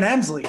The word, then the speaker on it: Hemsley: He's fucking Sherman Helmsley. – That one, I get Sherman Hemsley. All right, said it wrong too.